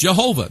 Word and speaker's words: Jehovah: The only thing that I have is my Bible that tells me his name is actually pronounced Jehovah.